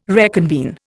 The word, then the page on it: reconvene: Indian